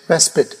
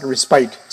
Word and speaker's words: respite: This word, the correct pronunciation of it is respite. It's not respite.